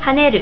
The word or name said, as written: haneru